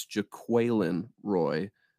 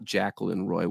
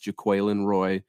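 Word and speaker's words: Jaquelin Roy: It's Jaqueline Roy, not Jacqueline Roy, which a lot of fans were saying early on. It's Jaqueline Roy.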